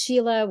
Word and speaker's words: Sheilagh: My name is Irish, so it's Sheilagh with a G-H that is silent.